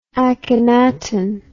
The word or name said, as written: Akhenaten